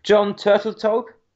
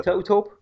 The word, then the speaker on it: Turteltaub: I always butcher this guy's name. I'm doing a book now. John Turteltaub? – Yep. – Turteltaub? – I think that's right.